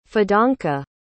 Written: fidanka

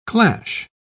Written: clash

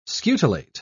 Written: scutellate

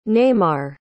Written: Neymar

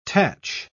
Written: tach

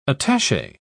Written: attache

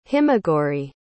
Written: himagouri

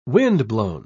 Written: windblown

